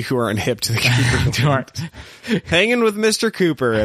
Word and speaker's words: Cowper: The Cowper gland. – Ew. It's pronounced Cooper. You Philistine.